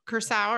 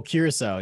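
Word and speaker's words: curaçao: Triples, or Cointreau, triple sec, or orange curacao, or how do you say that? – Oh, curacao, yeah.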